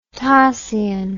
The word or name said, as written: tasian